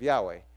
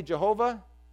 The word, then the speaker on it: Yahweh: And then also, when we say Jehovah, that's kind of a mispronunciation of the same thing. Using those letters for Lord, Adonai, that were above, if you say it that way, you say Jehovah. Yahweh is the guess we have. Of course, nobody was pronouncing it much, right? So that's the best guess we have of how it should have been pronounced.